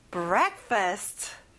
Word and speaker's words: breakfast: 아침 식사, breakfast. break에 세게 한번 말해보세요. breakfast.